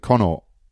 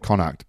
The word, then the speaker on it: Connacht: I've just called it Connaught. – It's not there, is it? – I've never heard you say it Connacht, but... – No, you told me the other day that you pronounce it Connacht, so I've just ruined your joke.